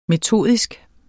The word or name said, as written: metodisk